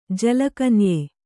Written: ಜಲಕನ್ಯೆ

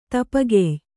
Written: ತುಪ್ಪಗಾಯ್

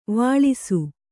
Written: ವಿಳಾಸಿ

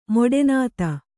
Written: ಮೊಡೆನಾತ

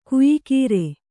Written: ಕೊಯ್ಗುರಿ

